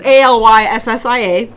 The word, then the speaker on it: Alyssia: So my name is spelled wrong, Alyssia. It's Alyssia, and McCaslin, McCaslin.